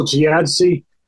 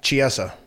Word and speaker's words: Chiesa: I was with great people, Chael Sonnen and Michael Chiazzi. Am I pronounce his name right? I hope so. – Chiesa.